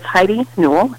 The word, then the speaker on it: Heidi Newell: It's Heidi Newell, H-E-I-D-I, Newell, N-E-W-E-L-L.